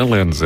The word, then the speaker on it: Lens: Lens, não é Lens, é Lens. – Lens, isto é que é pronúncia impecável de francês.